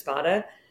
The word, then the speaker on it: Spada: My maiden name, we always pronounce it Spada, but it's pronounced Spada, like, the true Italian pronunciation is Spada.